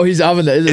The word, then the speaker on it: Avenatti: You got to say it right. It's Avenatti. – Avenatti? – Because he calls himself like Gotti. – Oh, he's Avenatti. – It's Avenatti. Like, Adi's like Gotti now.